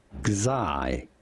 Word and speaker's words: Xi: This is normally pronounced Kazai. Kazai.